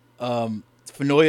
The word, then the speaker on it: Finoia: Same kind of thing, I get from them, and the comedy special I got from you is a guy named Mike Fenoya. Fenoya is spelled F-I-N-O-I-A.